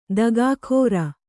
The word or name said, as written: ದಗಾಕೋರ